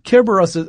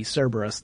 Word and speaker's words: Kerberos: Kerberos, that's the Greek pronunciation for, we often will make it a soft C, Cerberus, the three-headed dog.